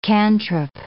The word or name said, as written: cantrip